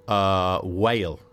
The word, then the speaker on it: Mhaol: I think it's pronounced Whale, but it's spelled M-H-A-O-L.